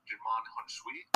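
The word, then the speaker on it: Djimon Hounsou: The one guard that obviously stands out is a young Jimon Hunshui. Am I pronouncing that right? Yeah, and it's like one of his first movies.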